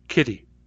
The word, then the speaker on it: kitty: We write kitty with a double T, but actually it's not pronounced t. It's not pronounced like the t in tiny. It's pronounced more like r, kitty, r, r.